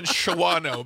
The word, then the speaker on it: Shawano: S-H-A-W-A-N-O. How do you pronounce it? – Shawano? – I say Shawano. – Do you know that my dad and stepmom live there? – Yeah, I didn't know that, but they live in Shawano because sorry, I'm a child of phonics. – No, it's pronounced Shawano.